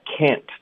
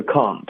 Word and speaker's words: can't: 我们要聊的是这个美式的发音，英式发音是 can't。然后这个 can't 的这个音呢，有的学生喜欢用一个 ang 的音， ang 不太对，可以用一个前鼻音 can't。那美式的发音呢就不一样了，我们会尽量保留这个梅花音，可是梅花音后面带一个 n 的音呢。